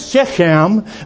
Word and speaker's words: Shechem: And I know I butchered that pronunciation, but it's S-H-E-C-H-E-M, Shechem.